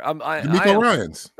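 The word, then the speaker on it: DeMeco Ryans: Oh my God, am I getting his name wrong in Houston? I feel like, help me here. – D'Amico Ryans. – No, I got it right, right? D'Amico Ryans. Yeah. Okay, yeah.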